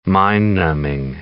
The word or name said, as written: mind-numbing